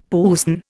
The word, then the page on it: puisne: Indian